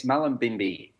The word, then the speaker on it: Mullumbimby: Now you're in Australia in Mullumbimby. Is that how you would pronounce it? – Yes, Mullumbimby, just outside Mullumbimby in northern New South Wales, up, near the border of Queensland, near Byron Bay.